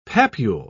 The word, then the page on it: papule: British English